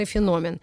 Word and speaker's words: феномен: И тема дискуссии «Город как культурный феномен». – С чего начать? – Ударение на «о» всегда. Феномен.